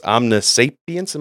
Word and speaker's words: omniscience: So it's omniscience, am I pronouncing that right? – Yep.